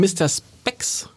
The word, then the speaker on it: Mr. Spex: Es geht um Mr. Spex.